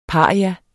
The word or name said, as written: paria